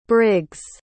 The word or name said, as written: brig's